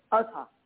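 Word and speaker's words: Ahaz: which has gone down in the sundial of how you pronounce it? Aza? – Yeah. – Azha. Okay, mm-hmm. Azha.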